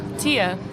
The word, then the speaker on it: Tia: Tia. – Tia? How do you spell that? – T-I-A. – T-I-A.